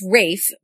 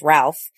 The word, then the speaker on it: Ralph: Besides making sure to pronounce his name correctly, yes, it's Ralph versus the Americanized Ralph, I wanted to hear more about his approach to such an iconic literary figure like Charles Dickens.